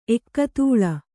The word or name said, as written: ಏಕತಾಲ